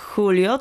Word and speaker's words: Julio: Mi nombre es Julio, czyli Julio to jest hiszpańska wersja imienia Julek, Julio. Tak? Mhm. A, to czekaj, ale pisze się przez J? – J. – Czyli to J czytamy jak ch. – Tak.